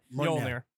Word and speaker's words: Mjolnir: And then Thor drops Mjolnir. How do you pronounce Mjolnir. – That? – Mjolnir. – What? – Mjolnir. – Mjolnir. – There you go. – Like millionaire.